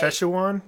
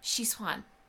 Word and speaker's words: Sichuan: Szechuan? – Yes. No, that's not how you say it. – Szechuan. – No, you're adding in a syllable in the middle, it's just Sichuan.